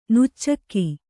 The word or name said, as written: ನುಚ್ಚಕ್ಕಿ